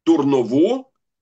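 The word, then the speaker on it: Дурново: Мертваго, с ударением на предпоследний слог, и две с ударением на последний слог. Турново и хитрово.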